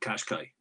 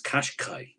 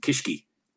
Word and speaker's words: Qashqai: But nobody could pronounce Qashqai, you know, Q-A-S-H-Q-A-I. – Squash. – So my favorite one that came back from Nissan Ireland, they have a list of what their dealers, people were coming in looking for. The best one was the Qishqi. So they came in looking, have you one of those Qishqis? I'd like a Qishqi, please.